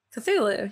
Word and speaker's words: Cthulhu: Cthulhu, yeah.